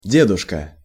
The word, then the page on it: deduska: Russian